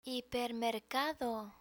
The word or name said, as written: hipermercado